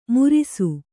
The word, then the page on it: ಮುರಿಸು: murisu